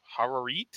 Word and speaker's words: Harareet: It stars Charlton Heston, Jack Hawkins, Haya Har- Harareet, if I could say that correctly.